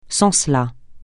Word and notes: sans cela: francais

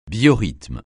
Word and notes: biorythme: francais

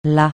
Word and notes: la: francais

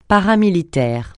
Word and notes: paramilitaire: francais

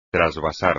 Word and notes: trasvasar: espagnol

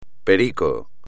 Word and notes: perico: espagnol

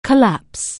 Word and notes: collapse: anglais

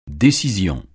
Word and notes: décision: French

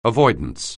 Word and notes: avoidance: anglais

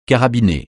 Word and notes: carabiné: francais

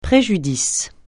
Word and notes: préjudice: francais

